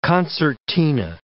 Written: concertina

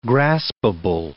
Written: graspable

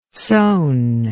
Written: soane

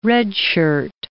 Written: redshirt

